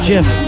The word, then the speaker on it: GIF: Yeah spelled GIF, but it's pronounced JIF.